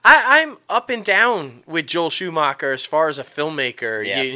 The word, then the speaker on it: Joel Schumacher: Joel Schumacher.